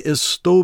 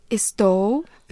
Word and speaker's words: Estou: Estou. – Estou. So listen to how she kind of goes, at the end of that word.